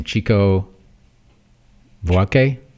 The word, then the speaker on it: Chico Buarque: And written by, I don't know how to pronounce his name, Chico Buarque.